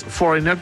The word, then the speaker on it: Foreigner: Y claro, es Foreigner, tal cual lo dice Nico. La G no se pronuncia, ya que la combinación es GN. La G queda muda, como design, claro.